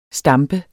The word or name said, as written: stamper